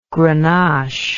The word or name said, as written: grenache